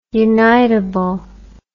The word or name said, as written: unitable